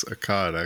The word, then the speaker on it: Akkad: And then in the north, you had a city of Akkad or Akkad. I don't know how you want to pronounce it. If you want to put a little southern twang and say Akkad. – Yeah, I think it's Akkad, actually. – Yeah, it is.